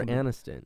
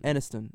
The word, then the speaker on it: Aniston: Jennifer Aniston. – Aniston.